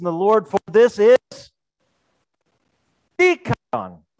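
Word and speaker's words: dikaion (δίκαιον): It says dikaion. See the accent on the beginning? Dikaion.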